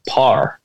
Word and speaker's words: Power: Like even down to something simple, like the word, like the surname Power, like P-O-W-E-R, everyone else says Power. They mostly have two syllables, but where I'm from, we would just say Par, nearly like it's one syllable.